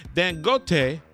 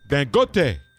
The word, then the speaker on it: Dangote: Dangote. Dangote. What is it? Dangote. Dangote. Did I say it right? – Uh-huh. – Dangote. – Right.